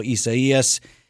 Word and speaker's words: Isaias: So that is the pronouncer, Isaias? – That's what I've seen multiple times over, because there are some other mispronouncers out Esaias. – There. Esaias. – Esaias. Esaias. Okay.